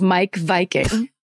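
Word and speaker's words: Meik Wiking: But then she also said that the author of that book that I said, remember it was like Miek Viking or whatever, his name is Mike Viking. And that's how it's pronounced.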